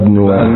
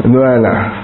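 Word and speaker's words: Neuenahr: Bad Neuenha. How do you pronounce it? Bad Neuenha. – Neuenha.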